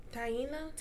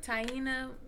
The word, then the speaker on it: Taina: How do you pronounce her name? – Taina. – Taina.